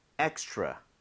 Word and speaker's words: extra: 여러분들이 R 발음을 혀를 제대로 당겨서 하고 있다면 extra처럼 치읓 비슷하게 소리가 날 겁니다. 자, 입모양을 모으고 한번 try 해보도록 할게요. 두 번 갑니다. extra, extra.